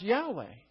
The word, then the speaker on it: Yahweh: Sometimes that sacred tetragrammaton is pronounced Yahweh.